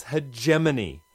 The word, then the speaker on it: hegemony: Hegemony? – Oh, jeez. No wonder it's frayed around the edges. Words are not meant to stand up to their secondary pronunciations. It's hegemony. Hegemony.